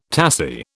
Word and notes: tassie: British English

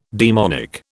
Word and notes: daemonic: British English

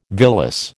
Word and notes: villus: American English

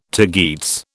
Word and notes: tagetes: British English

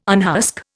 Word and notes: unhusk: Indian

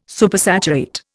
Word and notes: Supersaturate: Indian

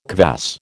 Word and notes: kvass: American English

